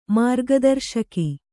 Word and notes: ಮಾರ್ಗದರ್ಶಕಿ: mārga darśaki

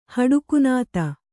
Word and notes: ಹಡಕುನಾತ: Kannada

